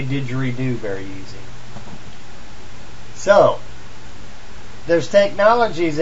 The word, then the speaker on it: didgeridoo: Buddhist monks used to chant Aborigines, I almost said that wrong, use the, I can't even pronounce it, Diridoo, is that it? Didgeridoo.